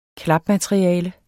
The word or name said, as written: klapmateriale